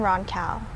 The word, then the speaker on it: Roncal: White people say it like Roncal. No offense to white people, but I'm just like, it's a short name.